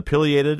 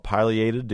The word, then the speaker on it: pileated: And it's in terms of the pileated, or some people call it pileated, depending on how you want to pronounce that, it's tomato type thing.